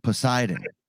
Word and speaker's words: Poseidon: The pedestrian? – Poseidon. – That's how it's pronounced.